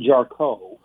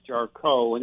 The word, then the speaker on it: Jarcho: It gets mispronounced all the time it, it looks like Jarcho and it's pronounced Jarko, and it's German and Polish.